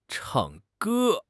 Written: chang4 ge1

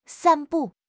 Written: 散布

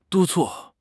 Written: 督促

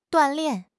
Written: duan4 lian4

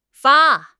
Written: fa1

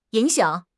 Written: ying3 xiang3

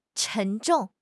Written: 沉重

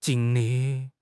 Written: jing1 li4

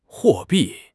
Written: 货币

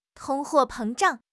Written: 通货膨胀

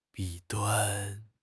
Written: 弊端

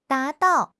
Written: da2 dao4

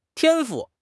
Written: 天赋

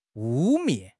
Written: 污蔑

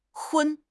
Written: 荤

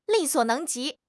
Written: 力所能及

